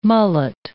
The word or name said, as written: mullet